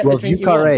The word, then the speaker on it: Vieux Carré: Yeah, it's V-I-E-U-X, and then new word, C-A-R-R-E. So I would say Vieux Carré, or I'm sure. – Oui. Yeah. – I don't know that carré is right in French. It's probably just car.